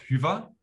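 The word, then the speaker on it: Hyvä: So it's pronounced Hyva. – Hyva. – Hyva in German and Finnish. There is no German, I guess. But yeah, Hyva. But it comes easy to Germans because we have the Ü, the umlauts.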